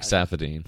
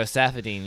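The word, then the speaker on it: Saffiedine: Rafael Dos Anjos versus Safadini. That was Tarek Safadine. – There we go, Safadine. Yeah, butchered that one.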